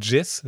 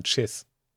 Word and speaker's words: Chiss: Nicht Jizz, sondern Chiss.